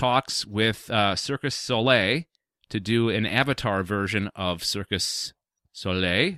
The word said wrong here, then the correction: Cirque du Soleil: Is that how I pronounce it? – Cirque du Soleil? – Oh, Cirque du Soleil.